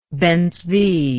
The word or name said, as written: Ben-Zvi